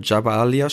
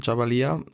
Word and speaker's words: Jabalia: Jetzt vor allem, als es eben um dieses sogenannte Flüchtlingslager ging in äh, Jabalia spricht man es aus, Jabalia.